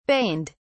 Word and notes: baned: British English

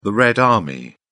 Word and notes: red army: British English

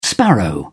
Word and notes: sparrow: British English

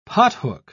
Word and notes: pothook: British English

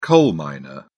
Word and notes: coal miner: British English

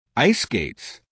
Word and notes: ice skates: American English

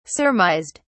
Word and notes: surmised: British English

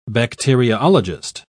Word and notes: bacteriologist: British English

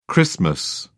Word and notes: xmas: British English

